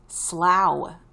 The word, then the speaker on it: Slough: Slog? Slog? Slog? I don't know. Slough, England. I was way off.